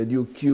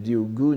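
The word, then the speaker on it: Ryugu (竜宮): Vous voyez, Shinryugu no Yukyo, entre parenthèses, Ryugu le palais, enfin on peut prononcer Ryukyu ou Ryugu, ici c'est Ryugu, n'est-ce pas, le palais des dragons, la lecture bouddhique.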